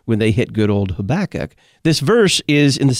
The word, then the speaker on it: Habakkuk: They knew this one, Habakkuk, because it sounds like tobacco or something like that.